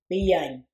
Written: biyany

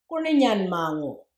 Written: Goorninyanmangoo

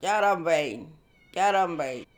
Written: Jarrambayiny

